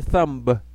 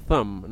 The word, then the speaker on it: thumb: No se dice thumb, la B no se pronuncia, sino thumb, nada más thumb.